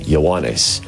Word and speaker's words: Ioannes: That's the Greek pronunciation of John's name, Ioannes.